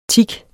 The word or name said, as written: tik